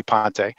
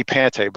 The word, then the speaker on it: Pante: I always go by Tony Pante, but my 17 and 16-year-old told me that the correct pronunciation is Pante. So the last several years, I've had to change the pronunciation of my name because of my two great boys.